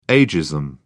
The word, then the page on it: agism: British English